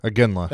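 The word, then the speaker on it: Iginla: Our last birthday, hockey player Jerome, I can't pronounce his Aginla. – Last. There you go. How do you say it? – Aginla. – That's it.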